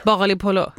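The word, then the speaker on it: baghali polo: Men alltså bagapol eller vad det heter. – Bagliapolo.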